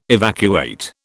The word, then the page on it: evacuate: British English